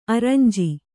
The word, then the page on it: ಅರಂಜಿ: arañji